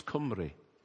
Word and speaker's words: Cymru: I honestly thought that, I mean, some of you might not know this, but the word Welsh in Welsh is Cymru. And I pronounced it Cymru. C-Y-M-R-U, Cymru.